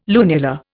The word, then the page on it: lunula: Indian